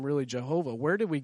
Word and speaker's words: Jehovah: Adonai, A-O-A. So what they did is they put it in between W-H-Y-H, which is Y-A-H-O-W-A-H, which pronounced would be Jehovah.